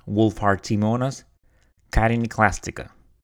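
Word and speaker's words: Wohlfahrtiimonas chitiniclastica: And you're going to observe that when I started doing the interview, I mispronounced it. I mean, I never dealt with this organism before, so my apologies. So it's Wohlfahrtiimonas Carinoclastica.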